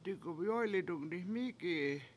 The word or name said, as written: digumyOy7li7dung diMIki7i